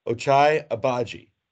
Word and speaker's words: Ochai Agbaji: Ochai Agbaji from Kansas, a 6'5 national champion.